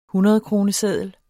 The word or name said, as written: hundredkroneseddel